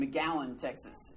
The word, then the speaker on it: McAllen: I was a personal assistant to a preacher, and we were down in McAllen, Texas. It's not spelt that way, it's pronounced that way. McAllen, Texas.